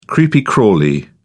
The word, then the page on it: creepy crawly: British English